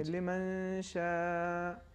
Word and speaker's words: لمن شاء: انہوں نے غنے کا تلفظ کیا, انہوں نے غنے کو کھینچا لیکن نون اظہار ہو گیا, تو صحیح تلفظ اس طریقے سے ہے لمن شاء, یہاں پہ اخفا ہوگا, البتہ یہاں پہ چونکہ شین ہے اخفا ہوگا, لیکن اہم نقطہ یہ ہے کہ نون کا اظہار نہیں ہونا چاہیے, نون کا مخرج مخفی ہو اور غنہ بھی ساتھ میں کھینچے لمن شاء.